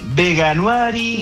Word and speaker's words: Veganuary: Lo dije horrible, le voy a preguntar cómo se dice. ¿Cómo estás? – Bien, muy bien. ¿Y tú cómo estás? Muchas gracias por la invitación. – ¿Cómo se dice, por favor? Sálvame. – Mira, la verdad, ¿cómo se dice? – Veganuary.